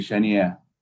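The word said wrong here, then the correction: Charrière: Charrier, aliás.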